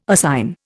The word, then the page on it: assign: Indian